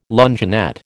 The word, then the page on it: luncheonette: American English